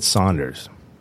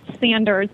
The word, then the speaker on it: Sanders: Is it Sanders or is it Saunders? – You know, it's phonetically pronounced Sanders, but even within the own Sanders family, some of them pronounce their name Sanders, some pronounce it Saunders. Really within the 1930s and 40s when we had a lot of European influence with the war, a lot of people changed the way they pronounced Sanders to Saunders. But Fred Sanders was the originator of the company, and it is correctly pronounced Sanders, but we do not correct anyone because obviously there's connections made to both pronunciations.